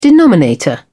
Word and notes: denominator: British English

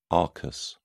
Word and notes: arcus: British English

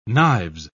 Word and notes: knives: British English

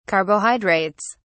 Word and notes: carbohydrates: British English

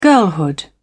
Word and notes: girlhood: British English

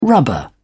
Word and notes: rubber: British English

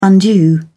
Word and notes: undue: British English

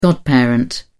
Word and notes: godparent: British English

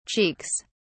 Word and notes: cheeks: British English

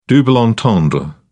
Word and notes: double entendre: British English

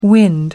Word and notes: wind: American English

